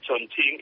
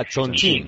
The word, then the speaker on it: Chongqing: Sí, porque es Chongqing, la Q se pronuncia como un H en mandarín. – Ah, Chongqing.